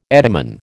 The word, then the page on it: etymon: American English